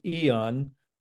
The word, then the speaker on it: AEON: Dr. Annabelle could probably address that, you know, the impact of, you know, a simple little EON patch, and that's spelled A-E-O-N, but a pronounced EON.